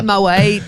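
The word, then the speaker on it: Moët: It's like the whole Moet Moet debate, isn't it? When it's like you actually realise that Moet is the correct way to say it, like poet.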